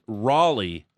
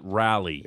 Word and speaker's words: Raleigh: It's pronounced Raleigh, not Rally.